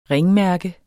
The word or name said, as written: ringmærke